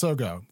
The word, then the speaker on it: Sogou: One of them that we wanted to talk about a little bit was Sogo. – Which I believe is pronounced Sogo. That we looked this up beforehand. It's spelled S-O-G-O-U in English.